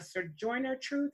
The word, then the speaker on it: Sojourner Truth: From Malcolm, Frederick Douglass, Sojourner Truth, if I'm saying her name right. – Yep.